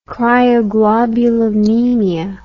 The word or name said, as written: cryoglobulinemia